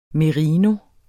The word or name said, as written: merino